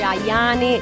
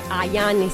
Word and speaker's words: Ayane: era il 2012 su Radio Latte Miele, tre cose di Malika Ayane. Non si dice Ayane, si dice Ayane, si scrive con la Y tra le due A del cognome.